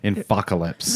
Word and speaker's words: Infocalypse: How do I pronounce that? Infocalypse? – That's what I would have thought. – Infocalypse. – The infocalypse.